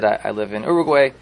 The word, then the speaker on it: Uruguay: There was a Yid, Rav Moshe Troib, that he came to Uruguay. Is that how you pronounce it? South America? Mm-hmm. Uruguay.